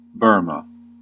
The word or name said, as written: Burma